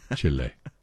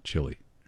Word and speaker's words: Chile: And my friend Harold says I have to pronounce it Chile. Chile, not Chile. Chile.